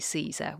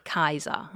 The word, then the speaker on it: Caesar: So that we don't say Caesar, we say Kaiser, and we don't say Weechi, we say Weeki.